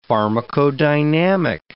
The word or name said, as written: pharmacodynamic